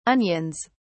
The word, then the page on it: onions: British English